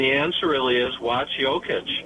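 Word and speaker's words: Jokić: Jokic? – Yeah, you got it.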